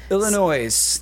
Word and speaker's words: Illinois: Illinois. The S is silent.